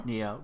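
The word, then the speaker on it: Nioh: Oh, so there's a rumor that the October PlayStation Plus games for PS4 are going to include Diablo 3 and Noah. – Noah? – I always say it wrong. – Neo? – Neo. God damn it.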